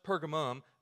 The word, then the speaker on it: Pergamum: You're sitting in the pew, you're sitting in the seat, and you're saying, man, I've got Pergamos. Is he talking about a different? No, it's the same one. Same church, same area, just a different pronunciation of it. No matter Pergamos, Pergamum, they were rewarded, they were praised for this action.